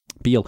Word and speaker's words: Bihl: Special thanks go to Matt Beal. I hope I'm saying that correctly. B-I-H-L. Beal.